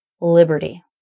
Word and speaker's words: Liberty: So the other tricky thing about this word is the middle syllable has an R consonant, and that's always tough. Liberty. And in this case, the vowel in the second syllable is the schwa, and the R consonant sucks up the schwa. It completely takes it over. So you don't need to try to make a separate vowel sound there. So it's just li-ber from the B straight to the R. Ber, ber, ber, ber.